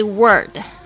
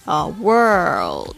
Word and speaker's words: world: 好，这边呢老师再仔细的讲解一下 Of all the books in the world，就是说在世界的所有的书里面，这边呢我们要特别注意一下这个 world 的这个发音哦，它不是 word 也不是 word world，就是你的舌头呢很明显的翘起来，要发这个 L 的这个 O 的这个声音， world world。